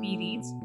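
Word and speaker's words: Phereds: P Reads is good, okay, guys? P H E R E D S. P Reads. That's how you say it.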